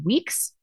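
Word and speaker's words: Wiiks: For those of you that don't know, Tina, I don't know how to say her last name. Weeks. It's W-I-I-K-S.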